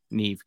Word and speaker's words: Neve: and then he just sees an angel, Neve Campbell. Neve, how do you say it? Nev.